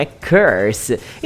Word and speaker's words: curse: a pronúncia perfeita de maldição é curse. E se você colocar um D no finalzinho dessa palavra, vira o adjetivo amaldiçoado. Saca a sua pronúncia? Cursed. Cursed. O que pouquíssimas pessoas sabem sobre essa tal palavra curse é que ela também pode ser traduzida por praga, blasfêmia e menstruação.